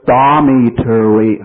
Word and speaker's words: dormitory: 这个地方呢，首先接触到一个啊，那么在美语当中呢，后面不是读的吹，而是读的比较蓝，给它伸长了，读成了 tree 啊，有点像 tree 啊。那么这个词呢，读下来应该是 dormitory 啊，这样来读的啊，dormitory。